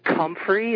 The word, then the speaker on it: comfrey: There's a plant or herb called comfrey. It's spelled C-O-M-F-R-E-Y.